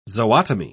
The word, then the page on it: zootomy: British English